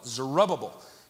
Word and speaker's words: Zerubbabel: The journey was led by a young man named Zerubbabel is the correct pronunciation.